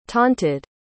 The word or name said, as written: taunted